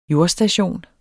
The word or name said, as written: jordstation